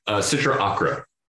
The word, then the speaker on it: Sitra Achra: And I'm saying that right, correct? – SITRA ACHRA. – ACHRA, SITRA ACHRA, like the Ocarina of Time.